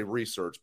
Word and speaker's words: research: Now, the research that I've done so far, I like to say research, but apparently you're supposed to say research. Put the emphasis on the wrong syllable, as they might say.